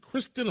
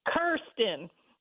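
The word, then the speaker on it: Kyrsten: Kirsten, how do you pronounce, is it Kristen or Kirsten? – Kirsten. But, she is being cursed by the Democrats today. So that's a good way to remember how you pronounce her name.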